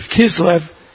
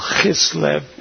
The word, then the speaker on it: Kislev: And for the Jewish people, Hanukkah is the 25th of Kislev, or as they pronounce it in the Hebrew Bible, Chislev, which is also the 25th of December.